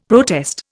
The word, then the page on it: protest: Indian